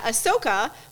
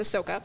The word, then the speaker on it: Ahsoka: So the first thing we have is that Ahsoka, which is how you pronounce it, Mike. It's Ahsoka.